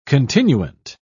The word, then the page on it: continuant: British English